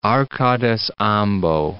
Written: Arcades ambo

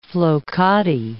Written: flokati rug

